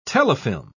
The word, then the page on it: telefilm: British English